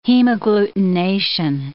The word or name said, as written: hemagglutination